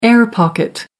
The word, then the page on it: air-pocket: American English